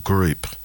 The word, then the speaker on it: Groop: This is The Group, and it's spelt G-R-O-O-P.